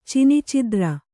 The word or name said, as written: ಚಿನಿಚಿದ್ರ